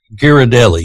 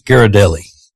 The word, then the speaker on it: Ghirardelli: Ghirardelli. – Yeah. Ghirardelli.